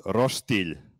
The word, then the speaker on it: roštilj: So Serbian barbecue is called roštilj.